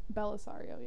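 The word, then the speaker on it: Bellisario: Belisario, yeah.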